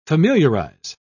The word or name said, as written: familiarize